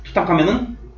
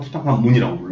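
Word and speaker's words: Tutankhamun: 투탕카멘은 프랑스어 발음하면 투탕카멘이에요. 근데 영어에서는 투탕카문이라고 불러요.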